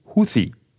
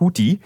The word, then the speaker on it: Huthi: Und zwar die Houthi, wie sie ausgesprochen werden, nicht Houthi, im Jemen.